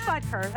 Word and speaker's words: Butker: Yeah, it is Butker. I said it right.